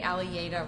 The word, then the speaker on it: Allie Yada: Say Allie Yada rhymes with alligator.